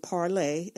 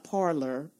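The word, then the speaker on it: Parler: the group and parlay, I'll say parlay – not parlor, but we call it parlor, but parlay means to speak in French. – Right. – So I'm just pronouncing it correctly, parlay.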